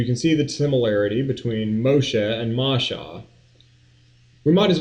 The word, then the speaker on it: Moshe: The word Moses, of course, is the English way of pronouncing the Greek way of pronouncing the actual Hebrew word. The original name is Moshe, and she chooses it because out of the water she drew him up.